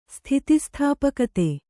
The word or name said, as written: ಸ್ಥಿತಿಸ್ಥಾಪಕತೆ